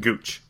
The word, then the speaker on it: Gooch: The captains were Phil, DJ, Sergio, Oosthuizen, Ian Poulter, Martin Keimer, Kevin Na, Graham McDowell, and Taylor Gooch? Gouch? How do you say it? – Gooch.